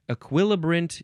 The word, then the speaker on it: equilibrant: Equilibrant, you can also say E, still emphasizing the second syllable, equilibrant. You can also say equilibrant, equilibrant, spelled E Q U I L I B R A N T.